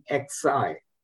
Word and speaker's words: Guanxi: Maybe I'm not pronouncing it very well. Okay. It is Guanxi.